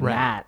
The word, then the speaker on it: Matt: When I introduce myself to people, I'll usually say Matthew, but people will call me Matt or Matthew. But if I say Matthew, there's no fuck up. If I say Matt, I'll get Nat. Is a very popular mispronunciation.